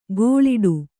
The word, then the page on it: ಗೋಳಿಡು: gōḷiḍu